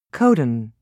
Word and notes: codon: British English

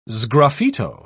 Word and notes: sgraffito: British English

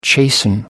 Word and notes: chasten: American English

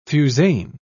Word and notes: fusain: British English

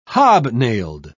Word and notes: hobnailed: British English

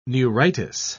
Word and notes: neuritis: British English

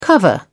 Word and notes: cover: British English

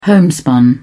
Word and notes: homespun: British English

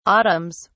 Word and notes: autumns: British English

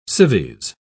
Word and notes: civies: British English

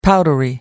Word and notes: powdery: British English

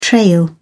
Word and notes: trail: British English

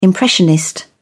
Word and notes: impressionist: British English

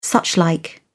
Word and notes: suchlike: British English

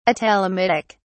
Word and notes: atelomitic: British English

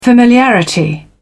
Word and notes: familiarity: British English

